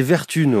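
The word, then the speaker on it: Vertumne: Ça s'écrit comme automne. – C'est Vertune, ouais.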